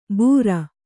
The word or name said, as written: ಬೂರಾ